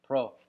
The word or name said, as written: pro